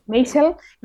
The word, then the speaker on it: Maisel: Y él también incluía en su lista de nominadas a mejor serie a Barry, a la maravillosa señora Maciel, eh, lo he dicho mal. Maisel,